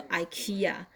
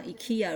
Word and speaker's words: IKEA: 我不知道各位有没有注意到今天的有一个新闻是那个 IKEA 家具哦，这个很很大的一家公司，我们都通常叫 IKEA。可是我记得我以前念那个杂志的时候，他们的发音好像就 IKEA。 Anyway，反正有人讲讲 IKEA，有人讲 IKEA，那反正你知道这家家具公司就可以了。它是全球很大的一家嘛，但无人不知，无人不晓。